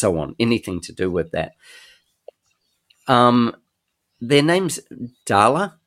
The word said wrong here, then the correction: Dallara: You can pronounce Dallara. Yeah, Dallara.